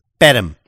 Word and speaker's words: Perm: Perm is how it's actually pronounced.